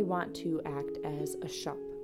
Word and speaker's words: scop: I'm taking my Celtic harp and I want to act as a shop. It's an old English word spelled S-C-O-P, but pronounced kind of like you're a little British and you are talking about a retail shop, right?